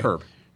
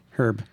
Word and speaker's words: Hryb: How do we pronounce that anyway? – Herb. – Herb. – Yeah, H-R-Y-B.